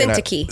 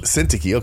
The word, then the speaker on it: Syntyche: How do you say that, man? – Let me see. – Let's see. Euodia. – Syntyche. – Syntyche.